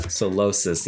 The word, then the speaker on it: Solosis: I did mispronounce the name so badly now that I found it. – Oh, my gosh. It does look kind of like that. – Solosis. Yeah. Yeah.